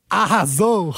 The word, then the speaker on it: arrasou: Am I saying that right? Arrasou? – Arrasou. – Oh, arrasou.